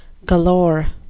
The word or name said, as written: galore